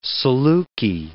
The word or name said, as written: saluki